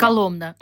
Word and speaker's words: Коломна: Вот, но это страшно забирает мой ресурс, потому что, например, вот завтра мы целый день со средним сыном проведем в Коломна, да, никак не выучу правильно ударение. – Коломна.